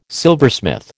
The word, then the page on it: silversmith: American English